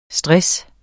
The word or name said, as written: STRESSEN